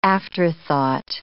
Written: aftertime

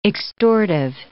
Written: extortive

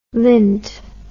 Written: lindt

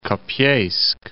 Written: Kopeysk

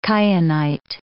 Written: kyanite

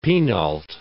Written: penult

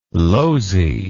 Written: Lozi

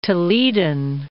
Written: Toledan

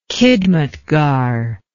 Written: khidmatgar

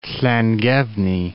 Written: Llangefni

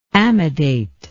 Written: amidation

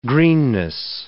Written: greenness

